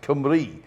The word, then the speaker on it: Cymru: It's it's spelt Cymru, but it's pronounced Cymru. Yeah. So for some reason, the U is a Y, and the Y is a U.